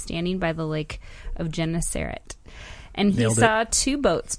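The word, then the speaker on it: Gennesaret: Yeah, and you pronounced it Genesaret correctly. – Now it's going to be... – Don't mess it up. – Genesaret? – Okay, Luke 5, 1 through 11.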